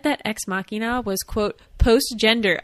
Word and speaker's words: Machina: Am I saying that right? Or is it Machina? – It's Machina.